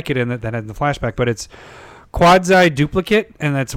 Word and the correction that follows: Quasi: Quasi Duplicate. I said quad. Like your quads.